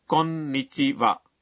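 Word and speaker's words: کونیچیوا (こんにちは): ویسے کونیچوا میں این کا تلفظ ذرا مشکل ہے۔ جی بالکل، تو اسے تین تال کی لے میں اس طرح کہیں، کونیچیوا۔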